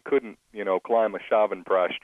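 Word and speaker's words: Chyavanprash: It's called Shavanprash. Shavanprash. And it's spelled Shyavanprash. So it's C-H-Y-A-V-A-N-P-R-A-S-H. So it looks like Shyavanprash. It's pronounced Shavanprash.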